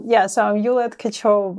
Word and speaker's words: Yuliia Tkachova: Thank you so much for inviting me and having Do you want to correct here. My pronunciation? – So I'm Yuliia Tkachova, but it's so much fun.